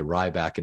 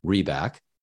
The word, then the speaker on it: Reback: People frequently will say Ryback instead of Reback, which I don't understand because, like your last name, it's spelled the way it's pronounced.